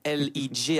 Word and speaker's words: L.E.J.: Alors, elle ne chante pas encore dans les Zéniths, mais c'était l'un des gros buzz de l'été dernier, le trio L.E.J., j'ai prononcé exactement. – Correctement. Merci. A ouvert Bourges hier soir également. Hein. – Oui, L.E.J.